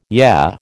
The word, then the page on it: yeah: American English